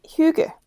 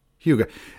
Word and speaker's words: hygge: Yeah, Hygge. – Hygge. Several years ago, the folks at Oxford Dictionary called Hygge, which is spelled H-Y-G-G-E, one of the most significant words of the year.